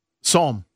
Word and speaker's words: Psalm: I'm going to say Palm West. I could be wrong, though. – What? How do you spell it? – P-S-A-L-A-M. – Psalm. – Psalm. – Psalm. Psalm West. – Okay. – Yes. – Yeah, that's Psalm.